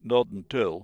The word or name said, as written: not until